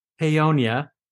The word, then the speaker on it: Paonia: So being in Paonia, did I say it right? – Good.